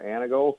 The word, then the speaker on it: Antigo: We kind of leave the T out a little bit here. We say more Antigo, but okay.